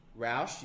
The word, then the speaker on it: Roush: Roche you shut your mouth. Roush, you numpty. – Roush. – It's Roush. According to Top Gear America, it's Roush.